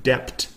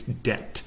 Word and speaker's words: debt: D-E-B-T. Now the B is just totally silent in this word. So instead of being pronounced like debt, it's debt.